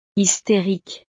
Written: hysterique

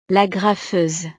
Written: l'agrafeuse

